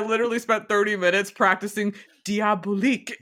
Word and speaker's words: Diabolique: so diabolique? Like, you mean it's not pronounced diabolix?